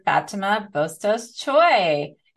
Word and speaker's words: Fatima Bustios Choy: Today, I have the pleasure of speaking with Dr. Fatima Bustios Choy.